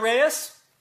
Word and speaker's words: Reyes: Yes, Amanda Reyes? – That's me. – That's the correct pronunciation of your name. – Absolutely correct.